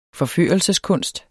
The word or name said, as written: forførelseskunst